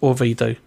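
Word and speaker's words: Owidju: I'm going to spell that for you. It's O-W-I-D-J-U, pronounced Orvidu.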